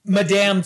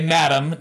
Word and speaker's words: Madame: Madame Tussauds, which we always say Madame, not Madame.